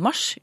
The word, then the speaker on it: Mars: Måneden mars med kort A og planeten mars med lang A, altså Mars.